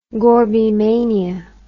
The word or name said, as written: Gorbymania